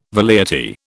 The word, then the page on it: velleity: British English